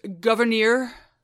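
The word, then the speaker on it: Gouverneur: According to Abigail Adams, his name is pronounced Governor Morris.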